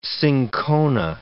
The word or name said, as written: cinchona